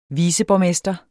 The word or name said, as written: viceborgmester